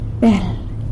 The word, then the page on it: bel: sanlucasquiavini